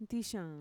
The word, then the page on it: ntixaan: Mixtec